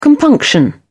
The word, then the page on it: compunction: British English